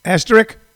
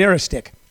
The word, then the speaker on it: asterisk: I always say this word wrong, asterisk. Is that how you say it? Asterisk. Yeah. Huh? – An asterisk. – An asterisk. I used to say it an aristic. I don't know why. Maybe because I'm lystexic.